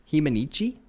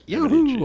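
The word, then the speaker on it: He Mni Can: But I believe it's Himanichi. – Yep. – Yay! Close, all yeah. right. Himanichi, yeah.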